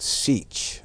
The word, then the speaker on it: Sich: It was called the Sich, and it's spelled S-I-T-C-H, right? Sich, that's how it's pronounced.